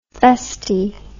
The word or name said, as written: feste